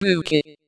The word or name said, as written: fairy